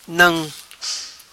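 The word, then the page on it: Nam: patua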